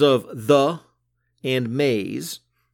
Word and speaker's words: Mays: The main character, you should know, her last name is spelled M A Y S, Maze.